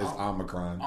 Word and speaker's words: Omicron: And Omarion? You about to dance up into your immune system, fam. – Omicron? Ooh, is that how you say it? – It's Omicron. – Omicron. Now, yeah.